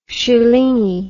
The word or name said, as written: shilingi